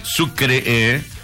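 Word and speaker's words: Sucré: Bueno, mira, Sucre, dice así, ¿no? Sucre, con acento en la E, Sucre.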